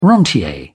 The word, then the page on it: rentier: British English